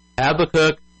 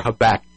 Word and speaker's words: Habakkuk: Now, I'm going to start out by saying there's two accepted pronunciations for that as well. Habakkuk or Habakkuk.